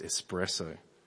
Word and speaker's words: espresso: And now I can, in grace and love, correct other people who are pronouncing it wrong. It's espresso.